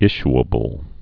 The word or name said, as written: issuably